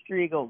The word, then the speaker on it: Strigel: Strigel. No, you're totally good. It's Strigel, but I'm doing well.